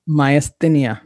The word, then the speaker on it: myasthenia: You mentioned that when you were in law school, you were diagnosed with myasthenia gravis. Am I saying that correctly? – Myasthenia.